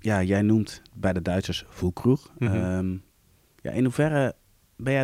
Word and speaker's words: Füllkrug: En dat bleek Morata en Füllkrug bleken dat te zijn. – Goed uitgesproken. – Zeg. Ja, dankjewel. Ja, Füllkrug. Ja.